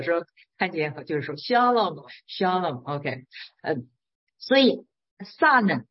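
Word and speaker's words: Shalom (שלם): 撒冷它发音呢就叫 Shalom。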